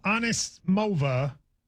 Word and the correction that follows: Anisimova: How do I say it again, Josh? – Amanda Anisimova.